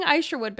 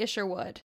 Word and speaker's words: Isherwood: Charles, and I've been saying Isherwood, but it's actually Isherwood.